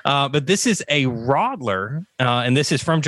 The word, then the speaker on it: Radler: Um, so, Bob, if you listened, if you were attentive enough and listened like we did, then you found out that uh, Radler, no, no, I said that wrong. How do you say it? – Rodler. – Rodler. See, it looks like Radler because I'm from the South.